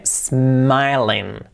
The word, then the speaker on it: smiling: empezando por S, sin la E, smiling, y la L, smiling, ok.